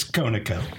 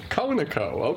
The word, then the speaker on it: Conaco: Why isn't it Conoco? Is it Conoco or Conoco? I like to give it a Hebrew pronunciation, okay. Oh. Hanako. What actually is it? It's Conaco. Conaco. Okay, all right. I screwed up.